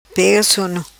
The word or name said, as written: Pega Sono